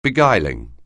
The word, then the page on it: beguiling: anglais